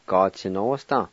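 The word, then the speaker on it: gatsinosda: Ga-ti-no-s-ta. Ga-ti-no-s-ta. Ga-ti-no-s-ta.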